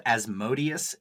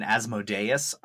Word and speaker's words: Asmodeus: So as it turns out, both Asmodeus and Asmodeus are actually both correct. You can use either one. – I refuse. – You sort of acknowledge the fact according to the wiki, you can use either one and they're considered to be correct pronunciations.